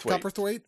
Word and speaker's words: Cowperthwaite: I'm probably saying that slightly wrong. Cowperthwaite. Do you know how it's pronounced? Because I don't know. – Cowperthwaite. I would say it like you. I think you pronounced it right. It was just I put too much emphasis on cow.